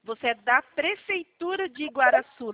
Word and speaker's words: Igaraçu: Fala de novo para mim o nome da prefeitura. – Prefeitura Municipal de Igaraçu do Tietê. – Igaraçu? – É, Igaraçu. – Igaraçu, falei certo. Espera aí então.